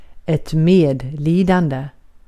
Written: medlidande

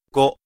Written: cinq

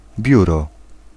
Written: byrå

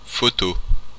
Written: photograph

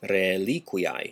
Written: rēliquiae

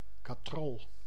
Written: Rolle

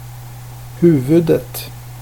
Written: huvudet

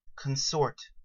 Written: consort

